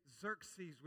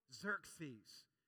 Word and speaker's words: Xerxes: Can you say Xerxes with me? Xerxes.